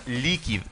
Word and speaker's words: Likid: La empresa se llama Liquid, que se escribe L-I-K-I-D Liquid.cl.